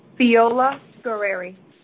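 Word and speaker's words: Feola-Guerrieri: Attorney Natalie Fiola Guerreri, Senior Assistant Corporation Counsel, is here for the respondent. – Natalie, how do you say your last name, please? – Fiola Guerreri. – Spell it, please. – F as in Frank, E-O-L-A, hyphen, G-U-E-R-R-I-E-R-I.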